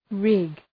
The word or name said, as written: rig